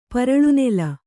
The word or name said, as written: ಪರಳುನೆಲ